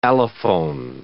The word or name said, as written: allophone